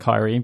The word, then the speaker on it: Kairi: Well, yeah, but I mean, like, Kairi, like, that really shouldn't be a name that works with English speakers, but it is spelt with an I and an I at the end, even though it makes an E sound. And I in the middle, Kairi.